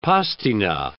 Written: pastina

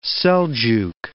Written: Seljuk

